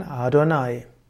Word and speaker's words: Adonai: Fromme Juden sprechen nicht aus, sondern wann immer in der hebräischen Bibel im Tanach Yod He steht, dann wird das ausgesprochen Adonai.